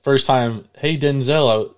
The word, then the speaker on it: Denzel: Fun fact, we've been pronouncing Denzel Washington's name wrong our whole lives. – Why was his name? – Because he pronounced his name Denzel, not Denzel.